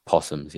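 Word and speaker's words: opossum: Horses, opossums, and cows. – Possums, yeah. Cows. – So they're not called opossums like it says on there. – I think that the O is silent, I think.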